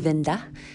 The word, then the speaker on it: Winde: It's spelled W-I-N-D-E. It's pronounced Vinda. R-I-E-N-S-T-R-A, if you want to look her up.